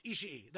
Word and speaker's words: Ishii: I don't know how to pronounce it. Tomohiro Ishii. – Ishii. That's right.